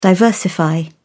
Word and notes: diversify: British English

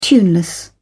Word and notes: tuneless: British English

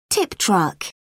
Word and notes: tip truck: British English

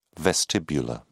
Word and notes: vestibular: British English

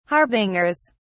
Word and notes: harbingers: British English

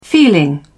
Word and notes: feeling: British English

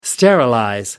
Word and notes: sterilize: American English

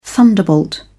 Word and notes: thunderbolt: British English

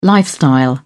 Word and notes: lifestyle: British English

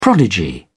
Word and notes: prodigy: British English

